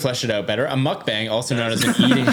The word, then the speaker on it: mukbang: It's also called mukbang, apparently. That's how it's pronounced. – I think that's the right pronunciation. – Yeah, mukbang.